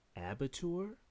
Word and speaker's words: abattoir: Abitur? – Abattoir? Spell it. – A-B-A-T-T-O-I-R. – O-I-R. I don't know that. Abattoir.